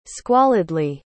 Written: squalidly

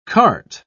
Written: cart

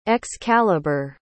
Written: Excalibur

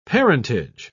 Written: parentage

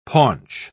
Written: paunch

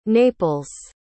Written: naples

